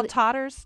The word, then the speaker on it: Tatars: Could any of the experts talk about the position and role of the Crimean Tartar minority during this crisis? And what are attitudes of Russians and Ukrainians toward the Crimean? Am I pronouncing it right? Tatars. And I apologize for that.